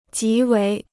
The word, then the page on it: 极为: jí wéi